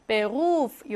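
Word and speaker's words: Beruf: 강세 받는 모음 뒤에 자음 하나. 베루프. 이렇게 길게 발음해야 합니다.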